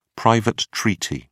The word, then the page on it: private treaty: British English